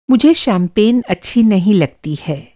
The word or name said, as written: शैम्पेन